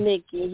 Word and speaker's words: Micki: Micki, yes.